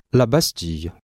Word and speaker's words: la Bastille: Обратите внимание на произношение слова la Bastille.